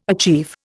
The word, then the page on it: achieve: Indian